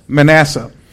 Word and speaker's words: Manasseh: Manasseh.